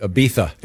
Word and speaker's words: Ibiza: She did it in Ibiza. – Oh, nice. – Huh. – It's Ibiza. – It's Ibiza, by the way. – Come on with that. – The Z is pronounced the TH. I'm sorry.